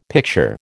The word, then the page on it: picture: American English